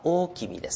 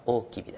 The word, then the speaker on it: 大王: で後には天皇と呼ばれましたけども、その大和王権の、中心となったのが、まあ、漢字では大王と書きます。大王ですね、読み方は大君です、ね、大王、大きな王と書いてね、読み方は大君ですね、はい、まあ、大きみを中心とした、政権ですね、大和王権と言いますけども、その大和王権の元になったんじゃないかというふうに言われております。